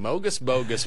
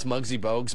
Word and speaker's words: Muggsy Bogues: Of all the ways to mispronounce Muggsy Bogues, Mogus Bogus.